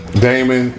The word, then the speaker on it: Damon: Out of all the Wayans, I like Damien the best. – Damon. – Yeah, I love him the best. – How do you mispronounce so many words, Mike?